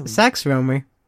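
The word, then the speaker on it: Sax Rohmer: And he goes by the name of Dr. Sex Romer? Sex Romer. – It just sounds funny. – I know. Sax Romer. Am I saying it right? – Because yes, Sax Romer.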